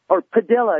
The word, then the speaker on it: Padilla: Well, or they talk about this guy that's in prison now, Padilla is the way he pronounces his name.